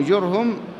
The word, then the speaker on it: جرهم: واسمه اختلف فيه اختلافا كثيرا، قيل فيه إنه جرهم بضم الجيم بعدها راء ساكنة فهاء مضمومة، جرهم ابن ناشب بالنون بعدها ألف فشين معجمة وآخرها باء موحدة.